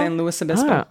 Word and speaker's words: San Luis Obispo: I really love that this person, and listen, thank you for all the corrections, but instead of saying like St. Louis, they said rhymes with poopis. – Yes. San Luis Obispo? – San Luis Obispo.